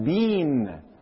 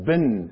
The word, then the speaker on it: been: And he looked at me and he said, I want you to pronounce one word. I'm going to spell it for you. B as in boy, E-E-N as in Nancy. And I shot back, oh, that's bean. And that's the way the Canadians say it. That's the way the British say it. That's the way the South Africans say it. And Uncle Chet turned to me and said, I'm sorry. In the United States, that's been, as in B-I-N.